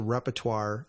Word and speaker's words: repertoire: So number six, that's rehearse the repertoire and not just, am I saying that right? You're the English major, repertoire. – Repertoire. – Repertoire, pardon yes.